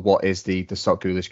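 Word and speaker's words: SocGholish: Am I right in saying that, Brandon? Sock Ghoulish or Sock Ghoulish? – Sock Ghoulish. I pronounce it Sock Ghoulish. It's kind of like Revel, Revel. There's different ways of pronouncing it. I just go by Sock Ghoulish.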